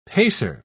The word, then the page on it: pacer: British English